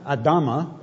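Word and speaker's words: Adamah: And the word Adam, Adama, as it's pronounced in Hebrew, literally means the mud of red dust.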